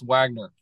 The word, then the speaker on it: Wagner: It's spelled Wagner, but it's pronounced Wagner for those listening.